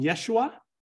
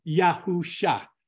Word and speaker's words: Yahusha: In Hebrew, some people call him Yeshua, which is closer. It's modern Hebrew, right? But we call him Yahusha. Okay, that's the ancient Hebrew pronunciation. So you got ancient Hebrew, Yahusha. Modern Hebrew, Yeshua, right? And then we have Jesus. And so we know it's not Jesus. It could not be. It's Yahusha, which is the ancient name, and it's the name that is prophesied in the Old Testament.